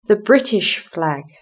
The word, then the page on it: the British flag: British English